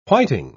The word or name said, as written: whiting